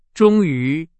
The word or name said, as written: 终于